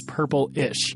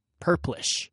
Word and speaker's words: Purplish: And as much as I wish it was Purplish, it's Purplish. But here's why I really wanted to talk to Gary Riskin, this former third grade teacher.